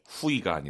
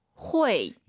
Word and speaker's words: 会: 후이가 아니라 발음 다시 들어볼게요. – 회. – UI 사이에 N 넣어야 돼요. 회.